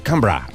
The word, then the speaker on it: Canberra: Que no se dice Canberra, se dice Canberra.